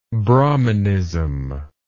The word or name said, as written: brahminist